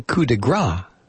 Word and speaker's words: coup de grâce: If you say coup de grâce, it sounds like the word for fat. So there you go. It's coup de grâce.